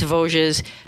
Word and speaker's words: Vosges: Vosges is spelled V-O-S-G-E. It's a French word. Mm-hmm. It's Vosges.